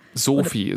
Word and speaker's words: Soofi: Sophie, S-O-O-F-I, haben wir gelernt, wird ausgesprochen, ein bisschen wie der Name, aber nicht genau.